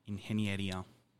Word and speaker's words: ingeniería: my favorite because it took me like a month to be able to pronounce it due to all the vowels. So my friend from Spain would always be like, say it with me, ingeniería. And I would say it really, really badly.